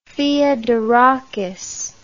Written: theodorakis

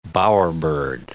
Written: bowerbird

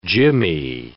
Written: jimmy

